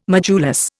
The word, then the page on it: modulus: Indian